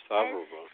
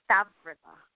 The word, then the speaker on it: Stavreva: Name is pronounced Stavreva. See, Spencer did well with that. Stavreva. – But Stavreva. – That just makes it better. – Why don't we have an artist like, you know, we should have an artist like Eric Satie on. It's easy to pronounce. – Oh, yeah. – You're trying to say star. You're trying to get rid of the V before the R. You're trying to say star instead of Stavreva.